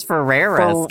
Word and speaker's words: Ferreira: I'm pretty sure it's Ferreira.